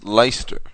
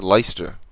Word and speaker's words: Leicester: So, believe it or not, that's actually pronounced Leicester. – Is it really? – Yeah, that's Leicester.